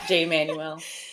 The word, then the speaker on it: Jay Manuel: Yeah, Jay Manuel.